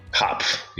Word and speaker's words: Hopf: Hopf, yeah. You Hopf, pronounce okay. The P and the F.